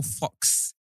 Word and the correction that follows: faux: How do you say F-A-U-X? Fox. – It's faux. – Is it faux?